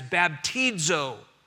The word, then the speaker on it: baptizo: And let me share with you, the Greek word for baptism, and of course the New Testament was written in Greek, is a word that we would pronounce as baptizo.